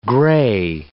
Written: gray

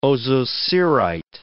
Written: ozocerite

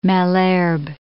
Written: Malherbe

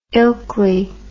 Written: ilkley

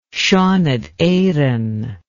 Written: Seanad Eireann

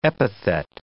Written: epithet

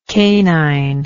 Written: caninity

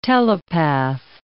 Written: telepath

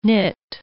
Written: nit

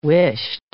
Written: whisht

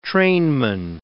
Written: trainman

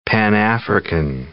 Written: Pan-African

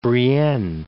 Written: Brienne

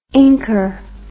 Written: inker

